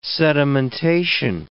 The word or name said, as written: sedimentation